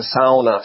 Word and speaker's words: sauna: And sauna, not sauna, right? Properly pronounced. – You want to do it right. It's a sauna.